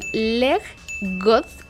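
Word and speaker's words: leg godt: son leg. God, no sé cómo se pronuncia, una disculpa. – Lo pronunciaste bien.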